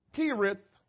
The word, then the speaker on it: Cherith: At the brook, Kerith is spelled C-H-E-R-I-T-H, but it's pronounced Kerith.